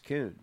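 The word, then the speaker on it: Kuhn: It's K-U-H-N. It's Coon. That's how it's pronounced.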